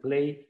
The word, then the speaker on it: PLAI: podríamos pronunciarlo PLAY en inglés,